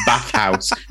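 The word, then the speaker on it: Bathhaus: Anyway, so my recommendation is Bathhouse, and house is spelled H-A-U-S.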